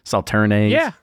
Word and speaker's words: Sauternes: Sauternes? – Yeah.